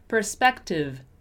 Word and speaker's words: perspective: We have three different sounds for the letter E there. We have first per, per, per. Then we have e, perspec, perspec. And then we have V, perspective, perspective, perspective. See, the letter E is pronounced in three different ways in this word.